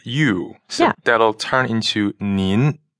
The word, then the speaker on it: nin: So the difference here is ni, just the simple you in pinyin is spelled N-I. – And it's third tone. – Right. And nin is N-I-N. – And that's second tone.